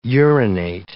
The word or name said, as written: urinate